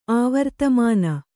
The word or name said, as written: ಆವರ್ತಮಾನ